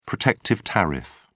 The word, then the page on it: protective tariff: British English